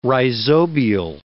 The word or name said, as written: rhizobial